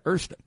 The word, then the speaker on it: Oyster Bay: In New York, we pronounce that Erster. We're going to go to Danny and Erster Bay. – Erster? – That's the Brooklyn. That's the Brooklyn pronunciation.